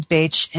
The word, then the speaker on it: Bach: So a beautiful man named Edwin Bache in England, we pronounce it Bach,